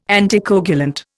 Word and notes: anticoagulant: Indian

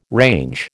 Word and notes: range: American English

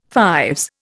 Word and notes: fives: Indian

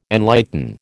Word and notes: enlighten: American English

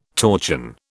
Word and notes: torchon: British English

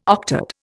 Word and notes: octad: Indian

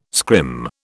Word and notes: scrim: British English

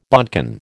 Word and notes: bodkin: American English

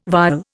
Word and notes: vial: Indian

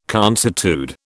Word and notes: consuetude: British English

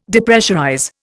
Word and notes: Depressurize: Indian

